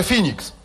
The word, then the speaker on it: Phoenix: To się pisze Phoenix, a czyta się Phoenix. I jest na P.